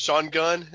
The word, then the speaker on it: Sean Gunn: It's Sean Gunn.